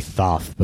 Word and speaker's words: Thoth: Yeah, they say Thoth, but I think it's actually pronounced Toth if you're going by the actual pronunciation.